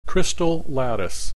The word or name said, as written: crystal lattice